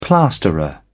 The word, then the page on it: plasterer: British English